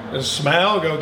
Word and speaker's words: Smaug: Smaug? Smaug, okay.